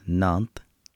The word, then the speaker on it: Nantes: I believe it's pronounced Nantes. – Okay. – A city in France.